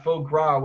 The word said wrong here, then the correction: foie gras: Phyllis always corrects me on that. What was it? What was it, Phyllis? – Foie gras.